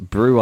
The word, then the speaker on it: Brut: Yeah, it is confusingly pronounced Brew IPA, but that's brew spelt B-R-U-T.